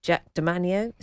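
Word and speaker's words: Jack de Manio: Jack DeManio, is that the right pronunciation? – Yes, oh yes, he was the chap who did the Radio 4 breakfast show.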